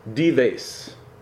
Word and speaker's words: Dives: The rich man was dives in Latin, thus Dives became the way to pronounce it in English.